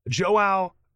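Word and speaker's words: João: Joao Carvalho. I think it's Joao. J-O-A-O Carvalho is an MMA fighter.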